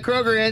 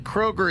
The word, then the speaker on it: Kroeger: The problem is people were like, hey, I got Chad Kroeger in. So, Chad, tell us. And he never had time to correct people that his name is not pronounced Chad Kroeger. It's pronounced Chad Kroeger, like Freddy Krueger.